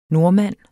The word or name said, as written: NORDMANDEN